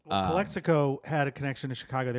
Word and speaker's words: Calexico: Calexico.